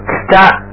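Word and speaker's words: kita (北): Okay, the north in Japanese is called chika, chika. And south is minami, minami. The next is the west is pronounced nishi, nishi. And finally, the east is pronounced as higashi, higashi. Okay, so kita, minami, nishi, higashi.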